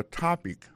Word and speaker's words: topic: What's your topic for today? Alo mot topic la ki signifi sujet, sa ve di aksan ton ek la tombe sou premier syllabe la.